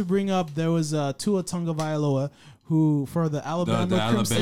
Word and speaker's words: Tagovailoa: But I can say the last name, Tagovailoa, is very simple. Tagovailoa. Say that three times fast.